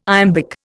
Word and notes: iambic: Indian

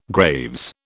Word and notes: Graves: British English

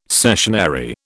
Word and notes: cessionary: British English

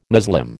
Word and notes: Muslim: American English

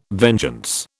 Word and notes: vengeance: British English

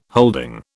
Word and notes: holding: British English

